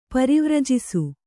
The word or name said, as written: ಪರಿವರ್ಜಿಸು